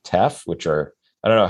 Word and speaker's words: teff: Teff, spell that. – T-E-F-F. – T-E-F-F, teff.